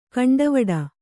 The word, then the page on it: ಕೊಂಡವಾಡೆ: koṇḍavāḍe